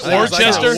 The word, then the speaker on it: Worcester: It was like Worcestershire. Worcester. – Worcestershire. Yeah. – Well, it's just like Worcestershire sauce.